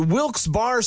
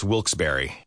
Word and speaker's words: Wilkes-Barre: And you're listening to Wilkes Barre Scranton's 97.9X. Now, I had heard that it was supposed to be pronounced Wilkes-Barre. Wilkes-Barre Scranton's 97.9X. The only way to pronounce Wilkes-Barre.